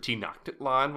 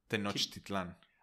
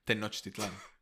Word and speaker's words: Tenochtitlan: Tenochtitlan. – Yeah, I forget you pronounce every letter in Spanish. – Like a regular person. Tenochtitlan.